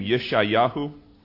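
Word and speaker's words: Yeshayahu (ישעיהו): You recognize the name Yeshayahu? He's in your Bible. You've read him. That's the way in Hebrew you pronounce Isaiah. We've butchered the language, have we not? Yeshayahu?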